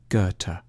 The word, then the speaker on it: Goethe: A safe bet is to start with a hard G on Ge and end with a T, Goethe.